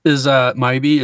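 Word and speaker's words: Mhybe: Like the maybe. – Right. I was like, maybe, but it's an H. So I'm like, Maivy.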